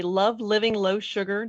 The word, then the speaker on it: Love Living Low Sugar: Kristi, you created the Love Living Low Sugar.